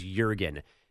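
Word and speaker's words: Jürgen: But since he's not from like Teaneck, New Jersey, you should have known that his first name was Jurgen, not Juergen. He's not really pronouncing the J.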